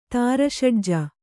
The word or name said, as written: ತಾರಷಡ್ಜ